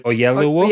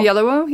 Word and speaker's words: Oyelowo: David, I always get his surname wrong, David Oyelowo. – Oyelowo, yeah.